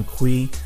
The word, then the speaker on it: Cui: My name is Brian Kui. Last name is spelled C-U-I on LinkedIn.